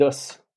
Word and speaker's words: Hoss: David Hoss. David Hoss, disons qu'à La Réunion, comme on en reparlera, mais on ouvre le O quand même,